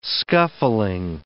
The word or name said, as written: scuffling